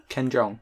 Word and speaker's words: Ken Jeong: Um, this is with two actors from Community, uh, Ken I uh, get his name wrong, Ken Jeong. – Ken Jeong.